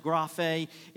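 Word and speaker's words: graphe (γραφή): The first is a Greek word pronounced graphe.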